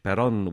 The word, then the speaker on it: Perron: My name is spelled Keith, K-E-I-T-H, Perron, which is P-E-R-R-O-N.